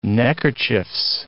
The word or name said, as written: neckerchieves